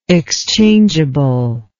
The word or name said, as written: exchangeably